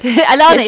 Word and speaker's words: Alanis: Alicia Morissette. – Alanis.